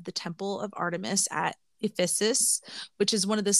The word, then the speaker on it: Ephesus: Like Ephesus in Western Turkey.